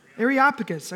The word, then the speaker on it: Areopagus: I had this word. I know how to say this word. How do you say it? Areopagus. Okay, yes. Said.